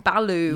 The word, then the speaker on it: balut: So this is balut. – Yep, so I had that balut. – Did you? Yep. Balut is how you say it.